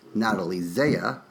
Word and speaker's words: Natalie Zea: Anyway, Dan, you may not know this, but Passions starred in their early careers Natalie Zea, which is actually pronounced Natalie Z, and her now husband, Travis Schultz, who was the dude meister in Scrubs.